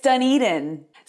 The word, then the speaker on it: Dunedin: It's Dunedin.